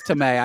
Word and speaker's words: Tomei: No, I know it's Tomei. I just said it wrong.